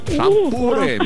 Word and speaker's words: шампур: Итак, запомните, Олечка, и вы особенно, что везде, как бы вы там ни переворачивали, ударение во всех формах существительного падает на букву «у». Шампуры, металлические шампуры, жарить на шампуре, есть с шампура.